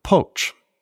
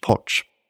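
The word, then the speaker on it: Pócs: The likeness is named after its place of origin, Poch. That's actually spelled P-O-C-S, but the C is pronounced like a C-H, Poch.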